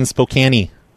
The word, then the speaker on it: Spokane: I'm from the zoning board here in Spokane. – What's that? – I'm from the zoning board here in Spokane. – In Spokane? – Yeah, here in Spokane. – You pronounce it right too.